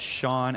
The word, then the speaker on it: Shawn: Just search Sean, S-H-A-W-N underscore D-Martell. That's spelled D-I-M-A-R-T-I-L-E.